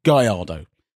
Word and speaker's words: Gallardo: It's not difficult to say Gallardo.